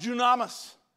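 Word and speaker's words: dunamis (δύναμις): In Greek, it's pronounced dunamis.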